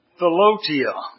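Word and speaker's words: philautia: But it's P-H-I-L-A-U-T-I-A. Philotia is what they say, is the way it's pronounced.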